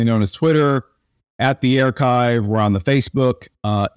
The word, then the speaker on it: Airchive: And uh, where do we find the Airchive? Well, first off, thank you for pronouncing it correctly because it is spelled, people sometimes people call it the air chive like an onion, but I can tell you it, it doesn't smell.